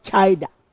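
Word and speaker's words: chida (חידה): In the Hebrew, it's called chida. You can pronounce it chida.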